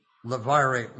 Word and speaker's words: levirate: Now, because I referred several times to levirate marriages, pronouncing it the way it's spelt, and that turns out not to be correct,